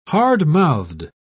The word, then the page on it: hardmouthed: British English